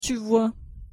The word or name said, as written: tu vois